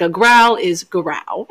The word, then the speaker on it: Gowrow: Now, this cryptid has many different pronunciations of the name. I have dedicated myself to the pronunciation of Garau. It is spelled G-O-W-R-O-W. So I have heard Go-Row, Go-Row, Garau. I'm sticking with Garau. It feels the best.